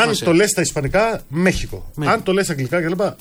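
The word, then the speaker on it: México: Άκου, το Μέχικο είναι Μέχικο ή Μεξικό, μου το είπαν τώρα στην Αμερική. – Για πες. – Αναλόγως αν, είσαι Μεξικάνος ή είσαι Ισπανός. Έτσι μου είπαν. Το προφέρεις Μέχικο.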